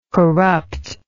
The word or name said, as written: corruptness